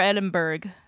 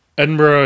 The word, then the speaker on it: Edinburgh: Edinburgh is correct. Especially if you're Scottish. But if you say it's Edinburgh, it's not like the end of the world in America, where anglicized pronunciations are always technically correct, thanks to colonialism. But Scottish people would rather you probably say Edinburgh.